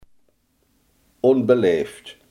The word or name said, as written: onbeleefd